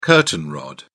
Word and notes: curtain rod: British English

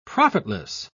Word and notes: profitless: British English

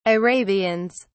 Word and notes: arabians: British English

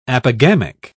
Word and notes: apogamic: British English

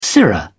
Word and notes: sirrah: British English

